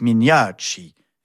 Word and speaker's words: Mignacci: Alors, elle se nomme la comtesse Mignacci. Est-ce que ça se prononce comme ça Oui.